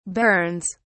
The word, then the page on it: bairns: British English